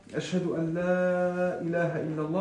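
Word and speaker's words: Ashhadu an la ilaha illallah (أشهد أن لا إله إلا الله): Er sagte, nein, so spricht man das nicht aus. Er nahm seinen Finger und zeigte in den Himmel und dann sagte er, Ashhadu an la ilaha illallah und hat es mit, so ausgesprochen, wie es ausgesprochen werden muss, ja, mit den Regeln der Sprache.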